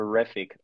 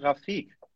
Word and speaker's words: Rafik: Also, ursprünglich hieß der Name, war der Name Rafik. Und das ist eine dumme Story. Deswegen, also man muss sich da auch nicht drüber Gedanken machen, was das jetzt bedeutet und warum das so ausgesprochen wird. Das war einfach nur englische Aussprache von einem Joke.